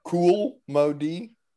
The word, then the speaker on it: Cool Mo D: He's like, do y'all remember Kumo D versus Rakim? – What? Did you just call him Kumo? – Kumo? – Do you pronounce the L? – You. – Cool Mo D? – Oh, you're right.